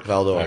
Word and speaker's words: Val-d'Or: Val-d'Or. – Val-d'Or, yeah.